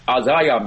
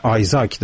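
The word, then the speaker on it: Isaiah: بله، نویسنده کتاب نخست توضیح بدن که درباره نام کوچکش اختلاف هست در تلفظش. در ایران آزایا میگن، آزایا برلین. اگر بر اساس اون انگلیسیش بخوایم بگیم باید بگیم آسایا برلین. البته این اسم از واژه عبری آمده، همون اسا فارسی است، یعنی آیزاک در حقیقت. – در واقع اگر بخوایم درست تلفظ کنیم همین تلفظ آیزاک هست که شما میگین، اما در ایران آزایا میگن، ما همین آزایا رو به کار میبریم.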